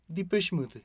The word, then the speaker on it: Depeche: Sí, y la pronunciación también puede variar porque varios le dicen el Depeche, Depeche Mode y bueno, si se van al, origen del nombre que ahorita vamos a aclarar, pues sí, es en, en francés y esa sería la pronunciación más atinada.